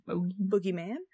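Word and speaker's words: bogeyman: The Boogeyman, also spelled like boogie, like boogers, but it's actually B-O-G-E-Y. – Like bogey. – Yeah, Boogeyman, but pronounced Boogeyman, is a type of mythical creature used by adults to frighten children into good behavior, which I find super fucked up.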